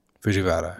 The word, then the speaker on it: Fujiwara: I can never say his name right. Tokuro Fujiwara. – Fujiwara. – Yes.